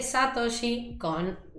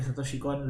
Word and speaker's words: Satoshi Kon: Y bueno, nada, la peli de la que voy a hablar yo va a ser Perfect Blue de Satoshi con De nuevo, probablemente esté pronunciando mal el nombre como siempre. – Está perfecto. Yo no estoy contando el trigger para poner aplausos porque Satoshi Kon lo amamos acá.